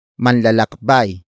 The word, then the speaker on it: manlalakbay: It is the focus marker that introduces the main topic of the sentence, and that is manlalakbay. Manlalakbay. Manlalakbay. Manlalakbay is spelled as M-A-N-L-A-L-A-K-B-A-Y.